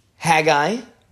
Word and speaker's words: Haggai: Haggai, which is the correct pronunciation for this.